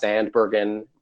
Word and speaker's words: Zandbergen: So you Google my name, Scott Zandbergen.